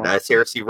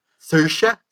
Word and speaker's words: Saoirse: As someone who works for an Irish company, I have to correct your pronunciation of Saoirse. – Saoirse?